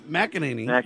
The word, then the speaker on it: McEnany: Don't worry, Fox News contributor and former White House press secretary, McEnany.